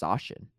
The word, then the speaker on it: Zacian: August 18th to August 31st, we got Zacian, or I think it's pronounced Zacian, actually, because that's how Gabby Snyder pronounces it, and I assume Gabby Snyder knows more than me.